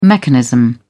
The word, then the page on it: mechanism: British English